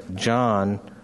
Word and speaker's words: Jean: I'm sure the uh, Stephen and Kevin, who are the older uh, sons, and uh, then John. Uh, it's spelled Jean, but it's the French pronunciation. John uh,